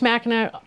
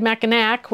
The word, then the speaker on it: Mackinac: And one of the days, 8 maybe, I don't remember exactly, we took a boat and went over to Mackinac Island. Sorry, I almost said Mackinac, which is how it's spelled.